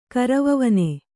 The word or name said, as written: ಕರವವನೆ